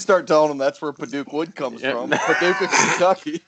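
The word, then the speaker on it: Padauk: African Paduke is Paduke. It is sometimes mispronounced Paducah, a city in Kentucky.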